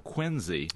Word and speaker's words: Quincy: Well, the unusual thing is that the first syllable of each of those two-syllable words sounds like a letter of the alphabet, Q-U-I-N-C-N-Y. And this spells out the city Quincy in Massachusetts. The slight flaw is that in Quincy, they pronounce it Quincy. Did you know that? It's a Z sound.